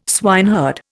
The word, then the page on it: swineherd: Indian